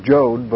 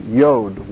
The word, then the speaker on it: Yod: Some Bibles have Jod, but it's Yod, Y-O-D. That's how it's pronounced.